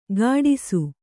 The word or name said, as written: ಕಾಡಿಸು